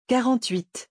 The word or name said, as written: 48